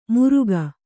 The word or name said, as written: ಮುರುಕ